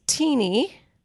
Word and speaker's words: Teeny: Christine, I hear your nickname is Teeny. – That's right. Yes. – Now, is that, it rhymes with bikini.